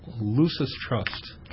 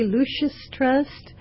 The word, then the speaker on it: Lucis Trust: What is the meaning of the name Lucis Trust? – Well, first of all, thank you for pronouncing it properly. So many people say Lucis Trust.